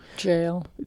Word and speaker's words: Gaol: Jail. – G-A-O-L. – It's pronounced jail.